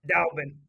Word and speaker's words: Dalbin: Dalbin.